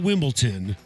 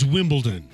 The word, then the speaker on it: Wimbledon: It's not Wimbledon, it's Wimbledon.